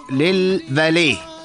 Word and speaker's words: فاليه: أمانة عمان الكبرى منع استخدام الأرصفة كمواقف خاصة أو للفاليه، لأنه الأرصفة حق للمشاة، يعني ممنوع واحد يجي يصف بهال على الأرصفة أو تكون مكان للفاليه. ترى هي بتنكتب فاليت بالمناسبة، بس التاء أو التي تكتب لا تلفظ.